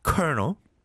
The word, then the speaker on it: colonel: Kernel, C-O-L-O-N-E-L. 이걸 누가 kernel이라고 생각하겠어요? 콜로넬이죠, 콜로넬. 그죠? But you pronounce it kernel. 그 다음에 또 이제 R과 L의 조합은 참, 아, 너무 힘들죠. Rural. Rural. 시골. R-U-R-A-L.